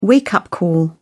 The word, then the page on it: wake-up call: British English